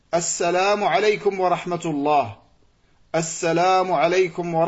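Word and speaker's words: assalamu alaykum (السلام عليكم): Beaucoup de personnes, quand ils font salam, ils disent salamu alaykum, salamu alaykum. Salam, on dit assalamu alaykum. Assalamu alaykum. C'est ça la prononciation correcte.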